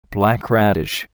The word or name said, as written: black radish